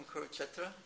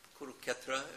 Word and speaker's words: Kurukshetra: But we see in the first chapter Is there anyone here from Kurukshetra? Or Kuruketra, if you're pronouncing it in a bungalow way.